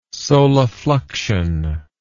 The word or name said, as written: solifluction